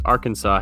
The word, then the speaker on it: Arkansas: One guy said, why does Arkansas have a silent S while Kansas gets free reign over the S being fully pronounced? – That's a real good one.